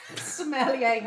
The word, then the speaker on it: sommelier: That's a very, apart from being a sommelier, how do you say it? – Sommelier.